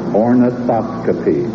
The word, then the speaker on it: ornithoscopy: Ornithoscopy.